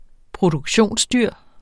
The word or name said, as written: produktionsdyr